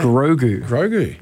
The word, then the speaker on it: Grogu: Grogu.